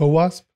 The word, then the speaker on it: OWASP: WASP, I can't even pronounce it. what's that community that sends out the top 10 threats every year? – Mm-hmm. OWASP? – Yes.